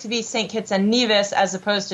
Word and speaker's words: Nevis: It's Nevis.